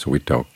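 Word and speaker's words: vitok (วิตก): The word for vitaka, directed thought, is vitok, and it rhymes with yok, which means to lift.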